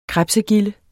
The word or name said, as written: krebsegilde